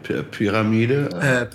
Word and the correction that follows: pyramid: Pyramid. – Pyramid, yeah, pyramid. I always say it wrong, pyramid.